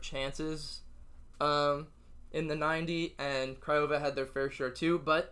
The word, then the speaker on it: Craiova: Hapoel Beersheva and University Tatai, I probably butchered that. – Yeah, Craiova. – Yeah, Craiova, easier to say.